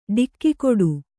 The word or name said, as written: ಡಿಕ್ಕಿಕೊಡು